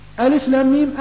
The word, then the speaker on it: الم: طبعا الآية يعني فيها أخطاء في الكتاب، ألف لام ميم ألم هذا خطأ، وأن محذوفة من الآية، فصححوها بارك الله فيكم في الكتاب. انظروا يا إخواني، ألم وألف لام ميم، الحروف هي الحروف ولكن النطق هو يختلف.